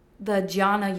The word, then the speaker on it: jnana: Like, I just started practicing the hatha yoga later in my life, but the jhana yoga. I don't know if I'm saying that right. – Yeah. – It starts with a J. – Yeah. J N A. Yeah. – J N A N A yoga.